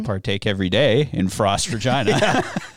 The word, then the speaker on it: Regina: The city of Regina, for those who are not from here, that is how you say it. – Regina.